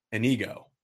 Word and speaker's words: Inigo: You are the firm director for the student agency, student run advertising agency, Inigo.